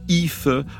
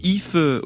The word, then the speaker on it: Aoife: elle s'appelle Aoife O'Donovan. Cette guitariste et pianiste américaine, qui a aujourd'hui une petite quarantaine d'années, a suivi un parcours aussi riche que varié. Aoife O'Donovan est une américaine d'origine irlandaise, comme l'indique son prénom qui s'écrit A-O-I-F-E.